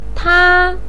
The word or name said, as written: ta1